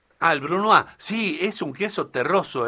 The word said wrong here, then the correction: Brunost: Se escribe Brunost. Sí. Yo lo pronuncié tal cual.